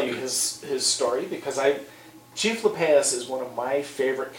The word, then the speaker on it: Lappeus: Lepaeus? – Lepaeus. – How do we spell that? – L-A-P-P-E-U-S.